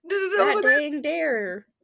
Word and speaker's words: Dhegdheer: One of the things said dag dare is what they pronounce it, but it's spelled D-H-E-G-D-H-E-E-R.